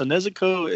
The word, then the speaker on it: Nezuko: The Figma Nezuko? – Yep. – Okay, so Nezuko is a character from Demon Slayer, and it's actually the sister of the main protagonist, Tanjiro.